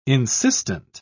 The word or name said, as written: insistent